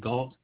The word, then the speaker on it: Gault: First name Brooks, B-R-O-O-K-S. Last name Gault, G-A-U-L-T. – Thank you for that. Spell that last name again for me, sir. – G-A-U-L-T, Gault.